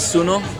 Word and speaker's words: suno: Like sun is suno, which is so easy to remember. – Suno. – S-U-N-O.